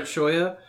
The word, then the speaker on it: Choya: How do you pronounce the name? – I pronounce it Choya.